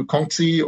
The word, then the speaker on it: Kongzi: Do I do Kongzi or Kongja, which is the way Koreans would pronounce it?